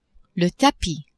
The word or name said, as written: tapis